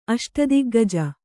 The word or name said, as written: ಅಷ್ಟದಿಗ್ಗಜ